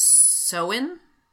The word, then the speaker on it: Samhain: It's pronounced Samhain. – Oh, okay. – From 2,000 years ago. – Spelled Samhain, but pronounced Samhain.